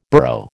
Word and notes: burro: American English

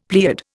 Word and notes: pleiad: Indian

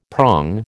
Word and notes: prong: American English

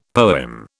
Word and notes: poem: British English

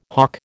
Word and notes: hough: American English

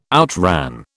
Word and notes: outran: British English

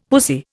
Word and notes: pussy: Indian

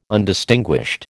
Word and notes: Undistinguished: American English

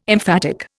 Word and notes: emphatic: Indian

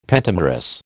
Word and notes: pentamerous: American English

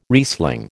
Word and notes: Riesling: American English

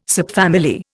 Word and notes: subfamily: Indian

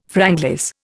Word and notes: franglais: Indian

